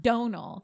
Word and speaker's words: Domhnall: Many videos of him pronouncing his own name, and it is pronounced Donal.